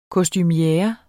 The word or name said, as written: kostumiere